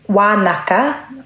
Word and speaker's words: Wanaka: My understanding is that, yes, it's Wanaka, which is the Ngaitahu pronunciation for Wananga. And Ngaitahu, a lot of our NGs are pronounced as Ks.